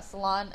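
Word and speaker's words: Salan: But yeah, salon and the spelling, like I said, everyone hears salon and they think hair salon, so they spell it with an O, but it's S-A-L-A-N.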